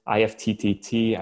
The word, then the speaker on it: IFTTT: You can leverage tools out there like Zapier, IFTTT. I think they pronounce it IFT now. I was on one of their webinars recently, and everyone kept referring to it as IFT.